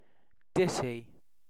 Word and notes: ditty: British English

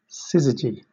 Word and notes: syzygy: British English

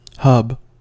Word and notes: hub: American English

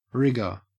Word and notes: rigour: Australian English

